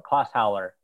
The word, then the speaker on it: Klosthaler: Klosthaler. It's, yeah, it's German.